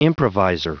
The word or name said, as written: improvisor